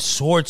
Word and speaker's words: sword: You pronounce the W in sword heavy the first time.